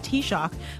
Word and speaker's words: Taoiseach: Yes, I just learned how to pronounce T-A-O-I-S-E-A-C-H, which is not Tao is each, it's T-Shock.